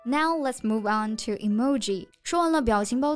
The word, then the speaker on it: emoji: 那它正确的发音呢，其实是 emoji。 That's right. It's important to put emphasis on the second syllable.